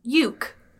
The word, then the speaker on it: Ueeck: His name is Elmo Uick. Uick. How would you say this last name? U-E-E-C-K. It's just a list of vowels. I'd like to buy a vowel. – Uke. – Great. Okay. Elmo Uke.